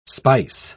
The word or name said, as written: spice